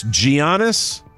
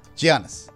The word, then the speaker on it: Giannis: It's Giannis. – Giannis.